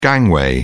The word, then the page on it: gangway: British English